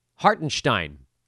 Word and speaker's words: Hartenstein: By the way, he has officially told everyone now that his last name has a Stein sound in it. What's with these NBA players that don't tell anybody the correct pronunciation of their name for 5 years? Nemanja Bjelica. He let everybody call him Bjelica for like a decade. Hartenstein, which does sound a lot more German.